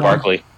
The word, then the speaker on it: Barkley: Well, the Giants didn't do too bad this season, even without Berkeley. or that's how you say his name? – Yeah, Saquon. – Barkley. – Saquon.